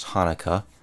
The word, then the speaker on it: Chanukah: Today, with all this multicultural crap, we have Merry Christmas, Happy Chanukah, which I guess is pronounced Hanukkah, and Happy Eid, which is the Muslim Christmas stamp. Remember that? E-I-D, which should be I-E-D.